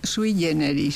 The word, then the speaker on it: sui generis: Con la pronunciación eclesiástica. – Efectivamente, porque el vulgo, diríamos, es muy sui generis, eso que has dicho. – Sí, mientras que en latín clásico diríamos sui generis. Ah. Fíjate si hay formas de decir una expresión. – Y el Papa ha dicho generis, que es la pronunciación eclesiástica más habitual desde el siglo XIX, que es la italianizante.